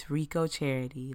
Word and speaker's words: Reco Charity: It's Reco Charity, like Rico Suave, but Reco with an E instead of an I, and charity, like, oh, you need to donate to a charity.